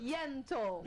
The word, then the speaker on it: Yentl: Ó, a querida Alessandra Maestrini, ela tá em peça, ela tem peça, que é a Ente. Ent in Concert. – Yentl. – Eu vou fazer com pronúncia Yentl in Concert.